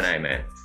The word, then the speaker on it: Gemini Man: No, no, ¿cómo se pronuncia en inglés? Gemini Man. – La Will Smith. Mm, sí, más o menos.